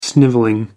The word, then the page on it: snivelling: American English